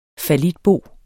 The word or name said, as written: fallitbo